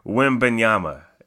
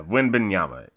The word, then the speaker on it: Wembanyama: He is the teammate of one Mr. Victor Wambanyana. I screwed up his name too. Hold on. Let me go back and say his name the correct way because Victor Wembanyama. Yeah, Wembanyama. Yeah.